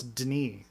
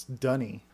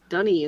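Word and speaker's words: D'ni: Yeah, it's really confusing, because in the books, it's like D apostrophe N-I. Like, that's Denis, or Denis? – Yeah, Denis. – Something Denis, like that, but no, it's Dunny. – Dunny is how they pronounce it.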